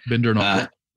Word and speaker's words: Bindernagel: Am I saying that right? – Yep, Bindernagel.